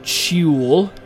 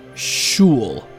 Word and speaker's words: Sheol: Now the Hebrew word for the word Sheol is is is the word Sheol, Sheol, um, and the, the the e is not pronounced very clearly in this word, so it's almost like Shul, something like that.